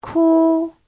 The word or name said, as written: ku1